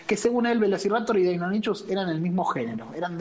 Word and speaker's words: Deinonychus: Deinonychus sería la pronunciación correcta, pero tengo la misma pronunciación que cuando tenía 5 años y lo leía. – y, además, para Velociraptor queda mucho mejor que Deinonychus.